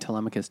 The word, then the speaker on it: Telemachus: And made Telemachus do it. Which is how that's pronounced, by the way.